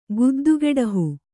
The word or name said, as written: ಗುದ್ದುಗೆಡಹು